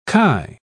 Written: chi

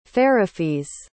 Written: feirefiz

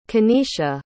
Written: Kanesha

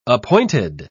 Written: appointed

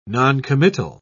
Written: noncommittal

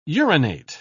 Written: urinate

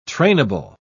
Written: trainable

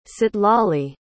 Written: citlalli

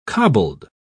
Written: cobbled

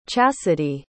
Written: chassidy

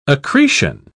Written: accretion

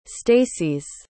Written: stases

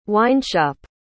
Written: wineshop

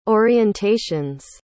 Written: orientations

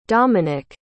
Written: dominic

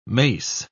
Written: mace

